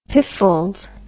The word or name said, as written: piffled